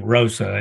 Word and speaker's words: Roosa: I appreciate you pronouncing it correctly, because everyone usually says Roosa. – Well, I had a former employer that was named Roosa, and he only had one O, so that was what gave me the clue.